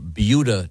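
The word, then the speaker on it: Buda: we have yet to get to sports, but we were discussing a very interesting news item from Beulah, Texas.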